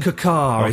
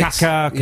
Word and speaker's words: Kaká: It's pronounced Kaká, is it? – Kaká. – Yeah, okay, yes.